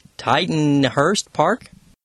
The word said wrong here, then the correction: Tittenhurst Park: Yes, she's mentioning the song Tittenhurst Park.